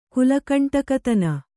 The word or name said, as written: ಕುಲಕಂಟಕತನ